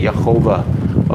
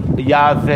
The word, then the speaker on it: Yahweh: So now, and the other one is Yod Heh Vav Heh, which is Yahweh, or what they say, Yehovah, okay, is the literal pronunciation of the word. And however, never pronounced that way in Hebrew, and is not pronounced that. It's pronounced Yahweh in Hebrew, because I don't know where they put the Degesh O in there, but it's pronounced Yahweh in Hebrew, okay?